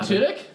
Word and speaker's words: Tudyk: Alan Tudyk? – Yes.